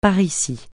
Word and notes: par ici: francais

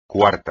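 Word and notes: cuarto: español

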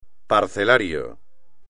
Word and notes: parcelario: espagnol

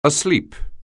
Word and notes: asleep: anglais

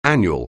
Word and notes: annual: anglais

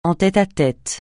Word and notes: en tête à tête: français

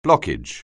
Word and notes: blockage: anglais

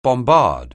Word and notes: bombard: anglais

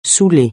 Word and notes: saouler: francais

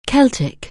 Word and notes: Celtic: anglais

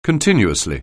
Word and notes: continuously: anglais

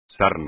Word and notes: sarna: espagnol

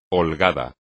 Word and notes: holgado: espagnol

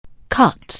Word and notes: cut: anglais

